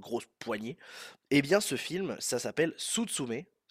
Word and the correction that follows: Suzume: Suzume, S-U-Z-U-M-E, e,